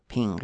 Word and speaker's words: PNG: the same modular philosophy as PNG or Ping, I guess.